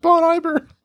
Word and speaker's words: Bon Iver: I think it's pronounced Bon Iver.